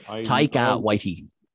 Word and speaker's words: Taika Waititi: But say, for instance, right, Taika Waititi, I can't pronounce his name. – Taika Waititi.